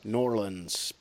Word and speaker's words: New Orleans: There's, some people call it Norlands. Norlands. That's kind of how they say it properly, Norlands.